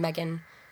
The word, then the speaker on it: Megan: Yeah, you can also tweet at me about the podcast at Megan Lily Flower, the name Megan, the flower Lily, and then the word flower. – And Megan is spelled M-E-G-A-N.